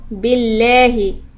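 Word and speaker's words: بالله: So when we say a'udhu billahi, so that lam is a soft lam. So we're going to say billahi.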